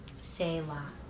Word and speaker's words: Selah: but there's a word that says Selah. It actually means to pause and think about it. So we all need those Selah moments, I think. – How do you spell that? – It's S-E-H-L-A-H. Selah.